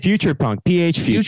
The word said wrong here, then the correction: Future Funk: I said it wrong. Future Funk. Future Funk.